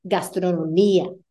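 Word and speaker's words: gastronomía: IA endings, you're only going to emphasize it if your I is accented. Otherwise, it needs to fall on the syllable beforehand. Memoria, historia, gastronomia. There I pronounce the, or there I emphasize the I.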